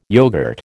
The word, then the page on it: yoghurt: American English